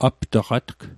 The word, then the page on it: aptaqatg: aptaqatk